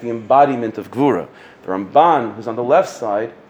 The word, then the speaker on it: Ramban: When you say Ramban, the accent is on the left side, Ramban.